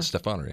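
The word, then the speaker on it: Stephaner: Is it Stefaner? – Stefaner, yeah.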